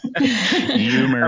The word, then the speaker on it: humor: I definitely have a lot of humor in there, and I know I say humor funny like I live in Philly or New York, but humor. Every time I say it, my wife gets on me. She says, you pronounce it with a Y at the beginning.